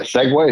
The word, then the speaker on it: segue: Wait, you definitely, that was definitely that segue. Is that how you say it? You segued into that.